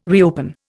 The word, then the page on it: reopen: Indian